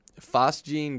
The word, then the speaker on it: phosgene: phosgene gas. You said it right.